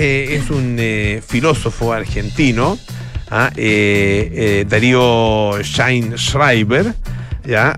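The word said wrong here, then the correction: Sztajnszrajber: Pero lo dije mal porque es Stein Schreiber.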